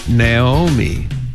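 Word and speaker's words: Naomi: 이렇게 연습해 주시고 나오미는 네오미. 한 번 더요. 네오미.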